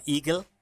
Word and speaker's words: iGel: I think it's supposed to be pronounced Eagle, since it's German.